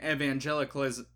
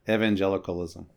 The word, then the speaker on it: Evangelicalism: But then months later, your memoir comes out titled Leaving American Evangelicalism? How do you say this word? – Evangelicalism.